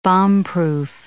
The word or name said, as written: bombproof